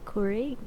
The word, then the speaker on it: Keurig: Our topic is 22F husband 26M won't let me give our Keurig away as a Christmas gift to a co-worker.